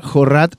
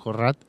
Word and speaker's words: Jorrat: Ah, y bueno, en razón de Sokin Mitch también, es que bueno, Jorrat, porque como es apellido libanés y no francés, hay que pronunciarlo así, dicen algunos, Jorrat, el director del Servicio de Impuesto Interno, como está vinculado con Martínez, también está en una posición bien compleja.